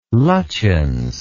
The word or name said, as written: lutyens